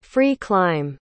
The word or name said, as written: free-climb